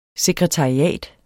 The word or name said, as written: sekretariat